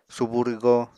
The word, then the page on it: subu rwgo: Apatani